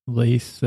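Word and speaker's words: Leith: I want to say Leth, but it's Peru Leth. Leth. No, it is Leth.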